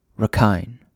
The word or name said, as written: Rakhine